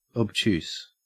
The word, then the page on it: obtuse: Australian English